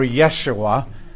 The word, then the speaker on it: Yeshua: And so the name Joshua actually means, or Yeshua, there are no J's in Hebrew. They're all Y's. And when the Germans came along and translated the Hebrew, they don't have any J's. They pronounce it like, or sorry, they don't have any Y's. They pronounce them like J's. So that's where you have Joshua.